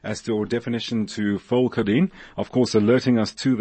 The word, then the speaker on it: Pholcodine: Pholcodine, Pholcodine. N-E. That is how it is spelt.